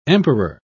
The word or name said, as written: emperor